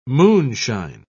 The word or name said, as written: moonshine